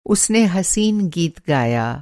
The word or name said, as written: हसीन